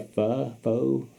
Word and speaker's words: pho: How do you say that shit? – Pho. – Pho. – Pho. Okay. My girl at the nail shop makes me pronounce it right. – Okay. – So, pho.